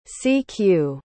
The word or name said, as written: cq